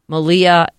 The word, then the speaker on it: Malia: So anyway, she's going by Shy now, and another famous person, one of the Obama girls, Malia, that's how you say her name, right? Malia. Yeah.